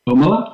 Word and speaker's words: Thomalla: Tomala, yes.